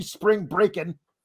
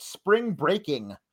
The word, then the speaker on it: Spring Breakin': Spring Breakin'. Josh Weaver makes a good point. Gacy didn't even say it right. He keeps calling it Spring Breaking, like the stepdad trying way too hard to be cool.